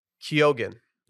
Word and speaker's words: Keoghan: In Ireland in 2022, and he said, Keoghan. – Yeah. – You pronounce the G, or at least I do.